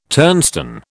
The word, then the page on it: turnstone: British English